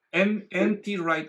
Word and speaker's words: N.T. Wright: N.T. Wright, así lo pronuncian.